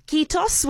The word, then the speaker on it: kiitos: Well, I'm going to finish with the only word of Finnish that I have learnt in advance of this interview, and I hope I'm going to pronounce it correctly in saying kiitos, which is the word for thank you. Is that correct, Heli? – That's very, very correct.